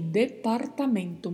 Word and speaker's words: departamento: Vamos à pronúncia aí, departamento. Muita atenção com a pronúncia do R, porque ele tem um vibrato, departamento.